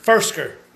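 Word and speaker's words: Firkser: Frisker? Is that how you say his name? Tight end there. – Frisker. – Definitely.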